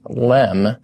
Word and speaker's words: LEM: LEM, pronounced LEM, it stands for Lunar Excursion Module.